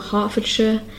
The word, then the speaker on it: Hertfordshire: So again, the ones we had here were Hertfordshire, Bedfordshire, and Cambridgeshire. And you can hear that I'm not pronouncing it as shire, but sh. It's spelled S-H-I-R-E, but I pronounce it more like S-H-U-H, like sh. So that's something that you can practice.